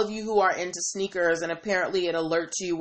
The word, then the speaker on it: SNKRS: And the app is called Sneakers, but it's spelled S-N-K-R-S, okay? S-N-K-R-S. So Sneakers without any of the vowels.